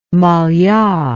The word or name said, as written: mallia